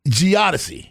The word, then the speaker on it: geodesy: how do we say that? Geodesy. – Yes.